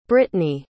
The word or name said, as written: brittni